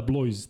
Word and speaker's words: Blois: Neko ko priča francuski, e, neka nam kaže kako se izgovara ovaj Ada Blois tim. Ada Blois e, je Ada pobedio Monaco 102-76. – Ada Blo. Ada Blo.